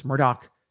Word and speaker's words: Murdaugh: In that case, Alec Murdaugh, who is a disgraced, and I keep saying Murdaugh because that's how it's spelled, but it's Murdaugh. That's how they pronounce it, is charged with killing his wife and son on their very large hunting property in South Carolina.